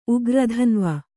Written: ಉಗ್ರಧನ್ವ

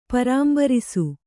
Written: ಪೂರಂಬರಿಸು

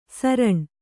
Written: ಸರಣ್

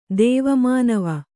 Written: ದೇವಮಾನವ

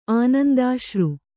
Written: ಆನಂದಾಶ್ರು

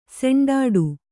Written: ಸೆಂಡಾಡು

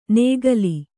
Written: ನೇಗಲಿ

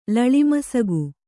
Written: ಲಳಿಮಸಗು

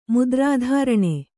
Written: ಮುದ್ರಾಧಾರಣೆ

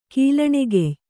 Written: ಕೀಲಣೆಗೆಯ್

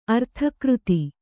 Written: ಅರ್ಥಕೃತಿ

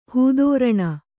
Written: ಹೂದೋರಣ

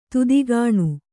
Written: ತೆತ್ತಗಣ್

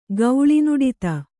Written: ಗೌಳಿನುಡಿತ

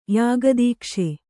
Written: ಯಾಗದೀಕ್ಷೆ

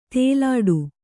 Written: ತೆಲ್ಲಟಿ

